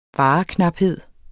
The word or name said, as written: vareknaphed